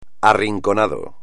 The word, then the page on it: arrinconado: espagnol